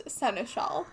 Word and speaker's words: seneschal: It is pronounced Seneschal, by the way.